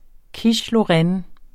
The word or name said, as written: quiche